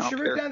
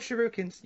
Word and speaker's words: shuriken: I probably said it wrong. – They are called Shuriken. they're called shurikens. – You, are good. Shurikens. Mm. I thought I said shurikens. Like like like, like they're shrieking. Shuriken.